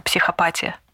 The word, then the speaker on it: психопатия: Вообще, Гриша, если честно, я только что погуглила, и правильное ударение – это психопатия. Простите, ради бога, мы исправимся прямо сейчас. Психопатия и социопатия.